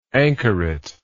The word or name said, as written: anchoretism